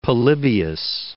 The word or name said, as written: Polybius